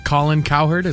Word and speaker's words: Cowherd: By the way, if you like seeing Colin Cowherd, is that how you say his name? – Yeah.